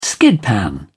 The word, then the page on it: skidpan: British English